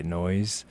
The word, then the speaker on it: Neois: Hi, my name is DJ Noise. It's spelled N-E-O-I-S, but I say Noise because it sounds cool.